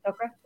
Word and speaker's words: Tok'ra: Right, Tok'ra? Did I say that right? – Yeah.